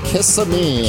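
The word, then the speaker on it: Kizza Me: Kiss-a-me. It's spelled like pizza.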